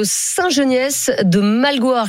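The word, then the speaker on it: Saint-Geniès-de-Malgoire: On est avec Benjamin qui nous appelle de Saint-Geniès de Malgoire. Je ne sais pas si je prononce bien, Benjamin. Bonjour. – C'est ça.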